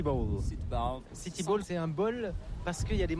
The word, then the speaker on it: bowl: Se prononce comme bowl, mais c'est bowl. – Bowl, mais c'est pas comme un bol. – C'est B-O-W-L. – Un bol comme dans lequel on met le café, par exemple.